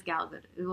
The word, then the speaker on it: Gal Gadot: Oh, yeah, it is Gal Gadot.